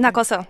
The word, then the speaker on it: Nakoso: at that shrine, and you say you switch to a local train to get off at Nakoso. Am I pronouncing that correctly? – Nakoso, yes. – And that's right.